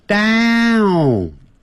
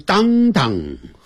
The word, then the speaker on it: downtown: downtown 先念大小的大，大大大大，嘴巴张得好开，downtown，不要念成当当，不是这样的发音哦。